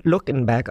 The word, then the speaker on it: Luckenbach: Im Ort Luckenbach, der von Deutschen gegründet wurde, das im amerikanischen Englisch aber nun Luckenberg ausgesprochen wird, finden jedes Wochenende Live-Musikveranstaltungen statt.